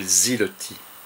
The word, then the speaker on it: Siloti: Siloti, qui se prononce en russe, paraît-il, Ziloti.